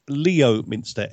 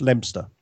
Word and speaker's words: Leominster: There is a place that is spelt Leominster, Leominster. It's pronounced Leominster.